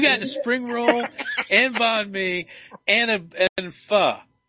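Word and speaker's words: bánh mì: Yeah, and next time I'll get the banh mi. And am I saying that right again? – Yeah, banh mi. That's right.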